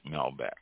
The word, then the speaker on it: Malbec: Malbec, M-A-L-B-E-C, is pronounced Malbec.